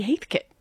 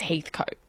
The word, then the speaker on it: Heathcote: And I said, I've heard it be Heathcote. And she said, no, you're crazy. It's Heathcote. Like, get it right.